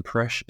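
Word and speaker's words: Presch: I am the only Alan Presh in the world. Presh is spelled like preschool, but without the ool.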